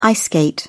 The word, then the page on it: ice skate: British English